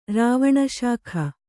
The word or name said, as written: ರಾವಣಶಾಖ